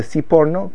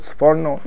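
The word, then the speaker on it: Sforno: The Siporno, sometimes referred to as the Siforno, but we like to call him the Siporno because he came from Italy. What? It's called Siforno? Nah, Siporno. The Siporno, if you ever want to get away with Yeshivish pronunciation, this is crucial. If you say Siforno at the Shabbos table, you've blown your cover, just like that. Because you're basing yourself on the English art scroll version, which has got this big thing emblazoned on the cover, Siforno, and it's Siporno.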